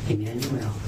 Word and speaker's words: Immanuel: I-S-A-I-A-H. – First name? – Emmanuel. – How do you spell it? – I-M-M-A-N-U-E-L. – Your middle name? – David. D-A-V-I-D.